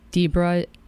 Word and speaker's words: Dibra: Dibra is D I B R A dot com.